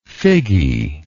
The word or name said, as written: figgy